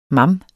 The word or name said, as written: mam